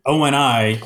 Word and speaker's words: ONI: It is definitely pronounced O-N-I because in that prologue scene when Halliday is like giving him the headset, an O-N-I headset can also be used to record its wearer's experiences in the real world.